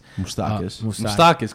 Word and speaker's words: Moustakas: Moustakas. – Come on, bro. You're Greek. – What'd you say? – Yeah, but Moustakas. I mean, that's how you say it in like the Greek part.